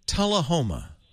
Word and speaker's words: Tullahoma: He's in Tennessee, little town called, am I saying it right, Tullahoma? – Yeah, you got it right.